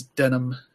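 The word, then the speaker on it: Denam: And so I had one, like the main guy whose name is Denim, not spelled like the jeans material, but it's pronounced the same way.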